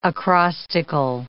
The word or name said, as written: acrostical